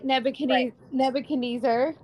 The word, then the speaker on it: Nebuchadnezzar: in, like these men, like what you said, they got thrown into the furnace because this king Nebuchadnezzar, right.